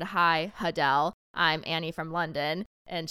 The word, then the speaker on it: Adele: We've all been pronouncing Adele's name wrong. – It's Hudel. – Hudel.